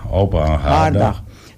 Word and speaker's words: hangaardag: Flying Focus luchtfotografie op het vliegveld houdt zondag 20 augustus van 10:30 tot 16:00 open hangaardag. Hoe spreek je dat uit? Hangaar? – Ja, open hangaardag.